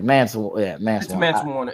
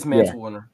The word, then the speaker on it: Mance Warner: I think I called them Manson. Yeah, I think it's Mance Warner. – It is Mance Warner.